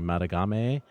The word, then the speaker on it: Madagame: Speaking of telling stories, I'm really excited about this Holden, I don't want to say his name wrong, Madagame. – I don't think that was right. – Yeah. – It was right. It was right. – All right, sure. That's how it's spelled. – I listened to the man say his own name.